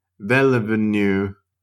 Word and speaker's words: Villeneuve: But no, apparently Mr. I'm going to butcher the name. Mr. Vinny Devenivenu. His name is Denis. Denis Villeneuve.